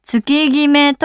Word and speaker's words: 月極: 20 代前半の頃、友達になんとなくの会話で、この辺月極駐車場が多いよねと話したら、月極駐車場って何と笑われ、月決めと読むことを教えてもらいました。